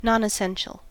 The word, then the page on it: nonessential: American English